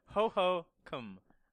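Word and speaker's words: Hohokum: The game is pronounced hobo cum. Ho ho cum.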